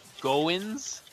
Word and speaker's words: Goins: Goins? – Yes.